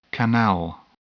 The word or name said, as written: canal